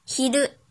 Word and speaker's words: 昼: 音读的时候读作 chu chu chu，比如午餐昼食昼食昼食，写成日语汉字是昼加食欲的食。训读的时候读作 hiru。